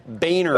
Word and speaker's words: Boehner: That's how it's pronounced, right? Have I got that right? Boehner. – That's correct.